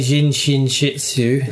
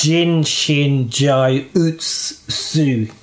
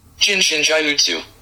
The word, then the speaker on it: Jin Shin Jyutsu: And I've been calling it Jin Shin Shitsu. – And it's actually pronounced Jin Shin Jai Utsu.